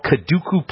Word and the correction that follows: Kakadu: Now, Kaduku Plum is, Kakadu Plum is how they say it, supposedly a very rich source of vitamin C.